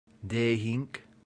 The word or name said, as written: dehinc